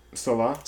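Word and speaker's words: Selah: I like Salaz. How you pronounce it? – Salah. – I mean, Follow God. Salah.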